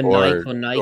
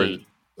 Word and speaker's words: Nike: Well, it's a Greek word, though. Don't you pronounce the E, Nike? – I mean, I don't know.